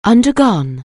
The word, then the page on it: undergo: anglais